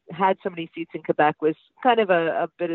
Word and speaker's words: Quebec: Quebec. I think I've said it. I said you've got to say it with a K, not with a Q.